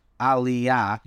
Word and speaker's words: Aliyah: And I'm avoiding that word because I'm going to butcher it. Aliyah. – You're doing great.